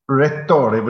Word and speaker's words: Rettore: Am I right in saying that your official title in the university there is Rettore? Is that the correct pronunciation? – Rettore, but if you want to give me my full title, it's Magnifico Rettore.